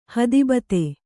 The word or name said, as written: ಹೇತುಭೂತ